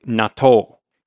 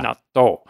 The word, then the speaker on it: natto: Natto, N-A-T-T-O. I also wanted to mention, in Japanese, I think the pronunciation is like natto. – The double T sound. – You put the emphasis on the second syllable, and yeah, there's a little gap after the T is kind of natto.